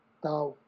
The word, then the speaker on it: Tao: Así te, con T de Tomás, porque también lo van a encontrar en inglés con D de Daniel Tao. – Mm. – Pero eh, sí, normalmente saca lo pronunciamos así con T y,